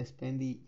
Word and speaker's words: Spendee: se, pronuncia Spendy, se escribe Spendy con doble e y eh,